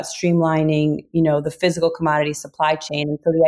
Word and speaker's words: CMDTY: So commodity, and by the way, we should say commodity is spelled CMDTY, the company name is, correct? – That's correct.